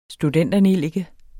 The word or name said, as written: studenternellike